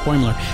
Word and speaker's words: Boimler: How do you pronounce his name? – Boimler.